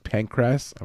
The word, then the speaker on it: Pancras: I know it sounds like pancreas, but it's pancras.